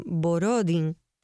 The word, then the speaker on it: Borodín: que de nombre Mijail Baradín, o aquí quien se lo pronuncian Borodín algunas veces,